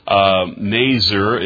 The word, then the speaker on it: Nazir: Nazir is the way it's pronounced in the Hebrew, as best that I can understand it.